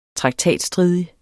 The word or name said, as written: traktatstridig